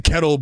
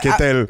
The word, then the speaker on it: Ketel: Is that how you say his name? – Well, they say Kettle, but it's the white guys. It's probably like Ketel.